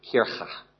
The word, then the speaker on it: Kirche: Difference between K and CH, that deep guttural down there, Kirche.